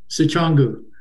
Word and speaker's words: Sicangu: Please jump in if I butcher this, because I am. Sichangu. Sichangu. Sichangu.